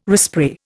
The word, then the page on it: respray: Indian